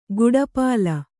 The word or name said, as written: ಕಡುಪೊಲ್ಲ